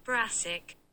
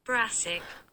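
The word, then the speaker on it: Brassic: Если вы смотрели «Галика», он же Брасик, по-моему, он так читается, я не стала даже пытаться произнести это в самом начале, но я сейчас сделаю, ха, напишу в Google переводчике, и он мне скажет, как это читается. Брасик. Брасик. Вот все услышали, как это произносится, я это повторять не буду, голяк.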